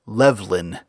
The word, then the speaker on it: Levlyne: I'll also use his version of Llewellyn, which is Levlyn, L-E-V-L-Y-N-E. And I'll pronounce that V as a V. It's possible it could have been a U or a W sound,